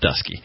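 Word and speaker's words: Dutschke: It's spelled Dutchke, but I read the GQ article, and Apparently it's pronounced Dusky.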